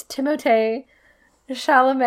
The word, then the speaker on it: Timothée: But at some point, he pronounced it, and he was like, it's Timothée Chalamet.